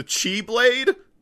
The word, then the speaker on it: X-Blade: It's spelled X-Blade. It's pronounced Chi-Blade, though, because the X it's using is Chi from the Greek alphabet.